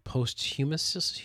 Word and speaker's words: posthumous: He is given, hopefully I'll pronounce this right, posthumous... – Yep, you did it. That's the way.